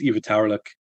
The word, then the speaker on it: Ivitaurlik: It's Ivitaurlik, which just means red char.